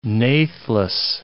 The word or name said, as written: natheless